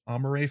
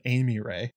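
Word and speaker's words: Amory: I am very bad about pronouncing that one. Amore Frey. For a while, I pronounced it Amy Ray,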